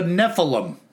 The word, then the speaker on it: Nephilim: I have a theory that a Sasquatch is left over from biblical days, that it's a Nephilim. I say that right? – It's a Nephilim.